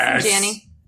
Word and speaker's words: Allison Janney: And next week, they're interviewing, I'm not going to say her name right, Allison Janney? – Yep, yes. Allison Janney.